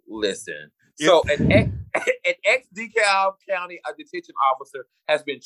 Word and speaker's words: DeKalb: So, Ex-Del, how you pronounce this? DeKalb County? – Yeah, DeKalb, yes.